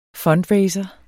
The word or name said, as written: fundraiser